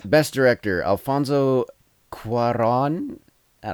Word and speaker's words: Cuarón: Yeah, the accent's over the O, so Cuarón would be how it looks.